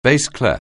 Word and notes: bass clef: anglais

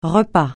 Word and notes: repas: francais

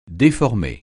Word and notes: déformer: French

